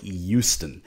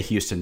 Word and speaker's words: Houston: First of all, I just like to point out where did you learn to say the word Houston? Because I've heard you say it a number of times now, and it's like Houston. You live here, dude. What what is that uh, pronunciation that you have? Hey, that's the way I was uh, raised. Very northeastern sounding for me. We say Houston down here, but uh, any case, University of Houston.